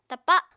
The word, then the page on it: tapa: Apatani